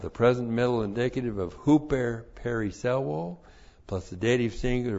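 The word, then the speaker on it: Huper (ὑπέρ): Huper periselwo. That's how it looks in the Greek. H-U-P-E-R. Huper. P-E-R-I-S-S-E-U long O.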